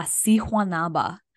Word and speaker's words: Sihuanaba: It's La Sihuanaba. And if you want to look that up, it's spelled S-I-H. U-A-N-A-B-A.